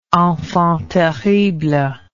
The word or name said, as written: enfant terrible